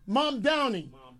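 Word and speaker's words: Mamdani: What, how you pronounce his name? – Mom Donnie.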